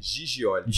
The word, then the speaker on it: Gigioli: Como é que fala o seu sobrenome? Giglioli? – Gigioli. Gigioli. É, é.